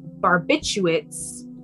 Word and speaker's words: barbiturates: An accidental overdose was ruled out because of the large amount of barbiturates. Is that how you say it? Barbiturates? – Yes.